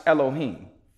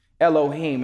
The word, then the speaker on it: Elohim: And the word for God here would be pronounced Elohim. Elohim.